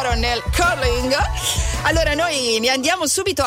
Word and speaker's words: curling: Diciamo che la pronuncia corretta sarebbe curling.